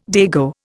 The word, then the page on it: dago: Indian